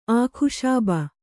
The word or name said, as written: ಅಕ್ಷೋಭ